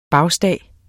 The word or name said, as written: bagstag